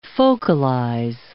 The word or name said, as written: focalize